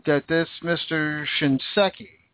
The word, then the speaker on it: Shinseki: The VA Secretary Eric Shinseki, I can never pronounce his stupid name, Shinseki. Shinseki, that's what it is. Shinseki.